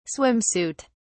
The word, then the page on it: swim suit: British English